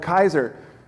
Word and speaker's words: Kaiser: Because if you see the C, we say it with an S, like Caesar. The Germans see it as a hard C or a K, and they called it Kaiser, but it's the same thing.